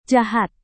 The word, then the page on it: jahat: indonesian